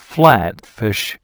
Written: Flatfish

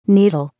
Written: needle

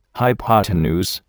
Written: hypotenuse

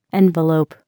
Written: envelope

